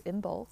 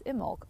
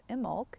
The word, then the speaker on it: Imbolc: and I am pronouncing it phonetically as Imbolc, and she is pronouncing it correctly as Imbolc, or rather she's pronouncing it in the way that is largely considered to be the most accurate.